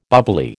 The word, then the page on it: bubbly: American English